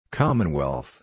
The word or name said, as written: commonwealth